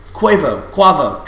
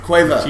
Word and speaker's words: Quavo: sweet sounds of Frank Ocean to fucking Quavo, trap music. – Quavo. Jesus, say it right.